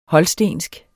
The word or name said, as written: HOLSTENSK